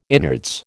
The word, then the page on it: innards: American English